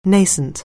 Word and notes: nascent: anglais

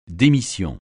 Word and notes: démission: français

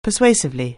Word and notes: persuasively: anglais

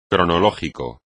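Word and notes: cronológico: español

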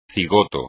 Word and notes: cigoto: espagnol

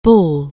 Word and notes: bawl: anglais

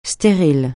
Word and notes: stérile: francais

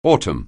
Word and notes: autumn: anglais

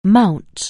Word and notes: mount: anglais

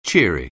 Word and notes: cheery: anglais

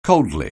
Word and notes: coldly: anglais

